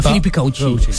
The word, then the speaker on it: Philippe Coutinho: C'est comme ça que ça se prononce, les gars. Hein. Philippe Coutinho.